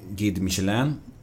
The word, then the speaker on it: Guide Michelin: Och de hade en restaurang som hade en stjärna i Guide Michelin. Är det rätt uttal? – Ja.